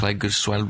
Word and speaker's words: Pleguezuelo: Um, is it Platziguelo? I'm probably going to pronounce that wrong. – Or yeah. Pleguezuelo, blah, blah, blah.